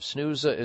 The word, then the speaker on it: Snuza: SNUSA is spelled S-N-U-Z-A.